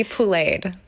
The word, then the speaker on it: pouléed: It's to be pouléed. So it's a poulet is the word for chicken in French. It's spelled P-O-U-L-E-T. So it's like to be pouléed, I think I spelled it, just added an E-D at the end, but it's yes. sort of essential. Like, phonetically, it would be like P-O-O-L-A-Y-E-D.